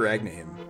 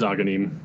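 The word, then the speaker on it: Agahnim: And yeah, I'm not even sure if it's here that Kakariko changes, or if it's until after Agnim. Or, see, I just did it. Ognim is defeated. – No, he's Aghanim. That's how it'll always be to me.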